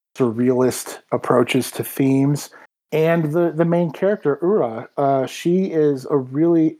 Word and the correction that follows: Uta: Am I saying her name right? Am I even saying her name in the ballpark? I don't remember. The main character is this girl who... Uta. There we go.